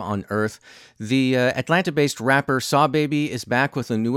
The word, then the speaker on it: Sahbabii: Barnacles by Saw Baby, and that's spelled S-A-H-B-A-B-I-I, Saw Baby.